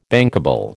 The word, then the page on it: bankable: American English